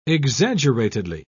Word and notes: exaggeratedly: British English

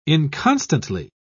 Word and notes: inconstantly: British English

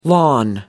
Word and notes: lawn: American English

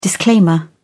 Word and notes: disclaimer: British English

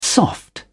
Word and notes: soft: British English

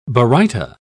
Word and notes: baryta: British English

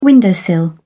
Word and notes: windowsill: British English

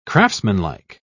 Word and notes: craftsmanlike: British English

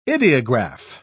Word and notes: idiograph: British English

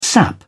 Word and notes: sap: British English

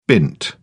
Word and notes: bint: British English

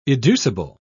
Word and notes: educible: British English

